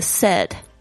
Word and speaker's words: said: 大家特别注意一下这个 said， S-A-I-D，它是 said 的过去式，但是要特别注意一下它的发音。 say A 是这个双元音 A 的声音，但是 said 是这个短音的声音。